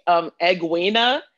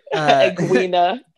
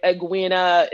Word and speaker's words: Egwene: So I definitely mispronounced her name. I was thinking Egwena. I'm not even, I was trying, I guess my mind was trying to make sense of her name. I felt like the G wasn't supposed to be there.